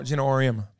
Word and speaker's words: Auriemma: I think what is it, Gino Amoretta? I know I'm butchering his last name, but the coach for UConn. – Auriemma, yeah, Gino Auriemma.